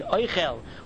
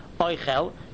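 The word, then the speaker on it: oichel (אוכל): That's the difference between oichel, which is a noun, where the accent is on the aleph and there is a segoil under the chaf, oichel, that means food. But when the accent is on the end of the word, and there is a tsere under the chaf, oichel, then it is a verb and it means to eat.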